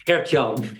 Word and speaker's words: Gertjan: Gert-Jan, yeah.